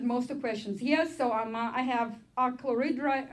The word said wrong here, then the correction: achlorhydria: how is it pronounced? Achlorhydria. It's called achlorhydria, no hydrochloric acid, just water.